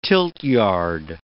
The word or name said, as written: tiltyard